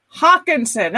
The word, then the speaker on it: Hockenson: Hockerson. Am I saying that right? – Hockenson.